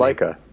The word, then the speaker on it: Laika: Laika.